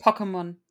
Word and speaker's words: Pokémon: Also für alle äh, zu eurer Info, mein Sohnemann äh, ist durch den Kindergarten total auf Pokémon jetzt Sag ich, habe ich das jetzt richtig ausgesprochen oder heißt ja, das? Perfekt. Ja, Okay, Pokémon.